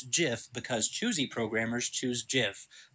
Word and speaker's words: GIF: Not That's, GIF. – Is uh, that what you're? Jesus Christ! Okay. Yeah. – All right. – Eh, it's graphic interchange format, so it's a hard G mm, on graphic.